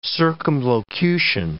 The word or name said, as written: circumlocution